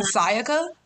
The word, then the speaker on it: Sayaka: like, nah, like, my name isn't hard to pronounce. I hear you guys, like, pronounce Worcestershire and, like, other fucking tremendous ass, big ass words that you look at me like I'm stupid for not being able to pronounce. But you're going to sit here and tell me you choked up on Sayaka? A three syllable name?